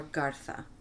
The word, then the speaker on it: Garza: Her surname is Garza or Gartha. – Garza, G-A-R-Z-A. – Or G-A-R-Z-A. – As you would say. – And here they would pronounce it Gartha, right? And then there they would say Garza.